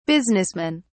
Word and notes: businessmen: British English

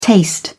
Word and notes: taste: British English

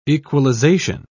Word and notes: equalisation: British English

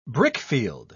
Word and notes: brickfield: British English

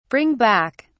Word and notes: bring back: British English

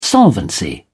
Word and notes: solvency: British English

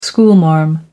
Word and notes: schoolmarm: American English

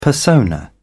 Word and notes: persona: British English

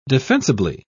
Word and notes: defensibly: British English

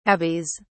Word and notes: abies: British English

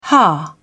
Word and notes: hah: British English

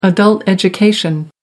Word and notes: adult education: American English

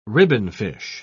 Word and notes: ribbonfish: British English

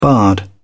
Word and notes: bard: British English

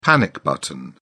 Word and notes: panic button: British English

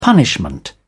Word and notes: punishment: British English

